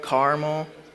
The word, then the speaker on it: caramel: Caramel, caramel. It's definitely caramel. Caramel. Don't pronounce the A in the middle.